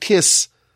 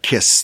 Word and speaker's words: kis: But the word kis, not like a kiss the way in English, right?